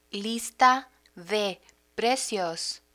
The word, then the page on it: lista de precios: Spanish